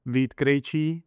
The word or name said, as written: Vit Krejci